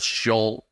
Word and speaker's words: Schultz: That's how you pronounce it, people. Schultz.